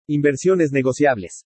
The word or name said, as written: INVERSIONES NEGOCIABLES